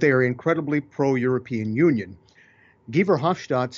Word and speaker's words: Guy Verhofstadt: His name is Guy Verhofstadt. It looks as though his name is Guy for us, but in Europe they would pronounce it Guy Verhofstadt.